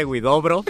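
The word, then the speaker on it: Huidobro: Vicente Guidobro, ya lo dije bien, perdóneme, señor Guidobro.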